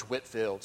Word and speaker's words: Whitefield: Um, if you read about American church history, the early part, uh, really kind of colonial, you'll read about George Whitfield, spelled like Whitefield, but it's Whitfield.